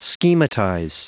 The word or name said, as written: schematize